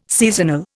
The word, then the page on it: seasonal: Indian